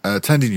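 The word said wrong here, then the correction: Thandie: No, it's Tandy Thandy. – Tandy Thandy. Newton is pronounced Thandy.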